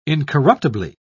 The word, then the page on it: incorruptibly: British English